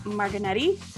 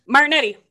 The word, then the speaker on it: Martignetti: Today on the podcast, we have Tony Martignetti. Did I say it right? – Yes. Close. Martignetti. – Martignetti.